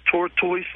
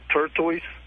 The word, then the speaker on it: tortoise: Wouldn't it have been tortoise and not turtoise? – I mean, you're splitting hairs.